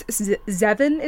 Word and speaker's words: Zeve: So this one is from, I believe it's Zeven is the way to pronounce it. Z-E-V-E.